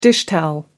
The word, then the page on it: dishtowel: American English